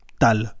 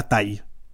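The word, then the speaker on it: Taal: Mon nom de famille c'est TA2L et les gens disent taille et euh, je leur ai écrit sur le mur. Ça se prononce comme ça. Tal, pas taille.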